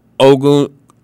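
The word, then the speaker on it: Ogundeji: And he's the one, well, co-pass rushers, if you will, that that the Falcons drafted along with, how do you pronounce it? Is it Ogunji? – Adekunbo Ogundeje. – Ogundeje. – Yeah. There we go. – Not a Gunji, but a Ogundeje.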